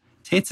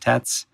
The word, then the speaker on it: Tetz: Tetz is spelled T-E-T-Z, so it looks like Tetz.